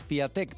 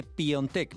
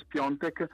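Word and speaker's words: Piątek: Ya no diré más Piatek, porque se dice Piontek, ¿verdad? – Se dice Piontek. Nos ha contado el gran jugador del pasado, Boniek, que la pronuncia correcta es Piontek.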